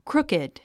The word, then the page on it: crooked: krúkid; クルキド